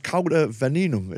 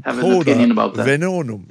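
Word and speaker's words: Cauda Venenum: So in Couda Venenum, is that the correct spelling or the correct pronunciation? – I'm not really an expert in Latin, though, but that's usually how I say it.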